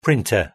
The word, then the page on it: printer: British English